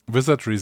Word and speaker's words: Wizardry: Wizardry 7? – Wizardry 7.